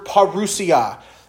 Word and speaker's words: parousia: It's the word parousia. It's spelled P-A-R-O-U-S-I-A. P-A-R-O-U-S-I-A.